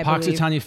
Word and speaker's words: Punxsutawney: Punxsutawney